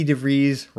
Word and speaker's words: DeVries: Yeah, along with the great Vicky DeVries, Rhymes with Cheese, we spearheaded a campaign to get Weird Al Yankovic a star in the Hollywood Walk of Fame.